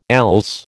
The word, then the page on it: else: American English